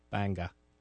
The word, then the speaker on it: Bangor: Banga.